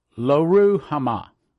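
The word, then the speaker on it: Lo-ruhamah: Now when she had weaned Loruhamah, pronouncing it correct, she conceived and bare a son.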